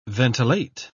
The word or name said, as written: ventilate